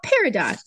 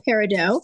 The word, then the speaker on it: peridot: Yeah, I think peridot would be the French pronunciation and peridot is everyone else, and both are okay.